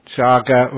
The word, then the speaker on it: Chaga: Chaga rhymes with Gaga.